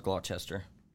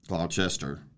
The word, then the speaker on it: Gloucester: It's it's pronounced Gloucester. Gloucester uh-huh.